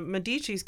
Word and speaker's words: Medici: And then when the Medicis came along, excuse me, I have to say this correctly, Medici,